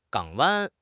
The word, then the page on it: 港湾: HSK6